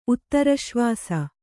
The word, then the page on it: ಉತ್ತರಶ್ವಾಸ: uttaraśvāsa